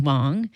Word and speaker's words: Huang: That was violinist Paul Wong, and that was pianist Helen Wong. Their last names are spelled the same, but they are not related. H-U-A-N-G.